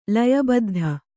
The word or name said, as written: ಲಯಬದ್ಧ